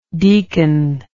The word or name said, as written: deaconship